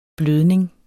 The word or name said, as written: blødning